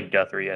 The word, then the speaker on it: Guthrie: Generally, Guthrie, I think, is how you pronounce it.